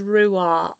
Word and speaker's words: Ruah: the transliteration of that is Ruah, R-U-A-H is how it's pronounced.